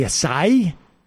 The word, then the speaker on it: acai: Emma, big fan of the acai, so taught me that's how you pronounce it, but I've enjoyed the pear, the strawberry, the lemon lime.